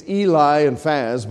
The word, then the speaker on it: Eliphaz: You have Eliphaz. I don't know if that's how you pronounce it. It's Eli and Faz, but I've always said Eliphaz.